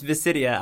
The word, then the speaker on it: Visidia: So, if you want to talk about it, I can just do, like, it's Visidia. I'll just give you, like, the pronunciation guide. – There you go. All right, so as princess of the island kingdom of Visidia, Amora has spent her entire life training to be High Animancer, the Master of Souls.